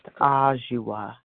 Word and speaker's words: Adwoa: A-D-W-O-A. But okay. – It's pronounced Ajua.